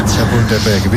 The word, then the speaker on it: Chapultepec: Chapultepec. Mejor que algún cementerio francés. – Chapultepec, bien, bien, bien.